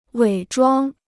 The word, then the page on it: 伪装: wěi zhuāng